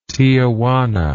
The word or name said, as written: tiajuana